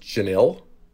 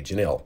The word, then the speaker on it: Jenille: This first one is from, I believe it's pronounced Janil. J-E-N-I-L-L-E, Janelle.